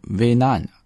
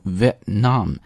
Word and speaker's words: Vietnam: 但是在读的时候呢不要把它读成 Vietnam, 而要读成 Vietnam, 这个 t 在这里有一个失去爆破啊，读的时候不要读出来，但是呢要把这个时长留住，所以是 Vietnam, Vietnam。